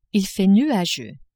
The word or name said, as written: il fait nuageux